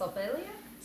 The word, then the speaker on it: Ophelia: My name's Ophelia. – Ophelia. – Oh, I think in English it's pronounced Ophelia.